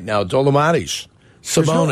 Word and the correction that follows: Domantas: There's no L in his name. – It's not an L? Oh, I see. Domantas. – It's Domantas, isn't it?